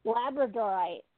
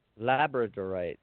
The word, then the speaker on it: Labradorite: Labradorite. – Labradorite.